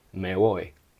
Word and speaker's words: me voy: But if you put them together, you don't say me boy, you say me voy, me voy.